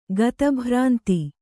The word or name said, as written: ಗತಭ್ರಾಂತಿ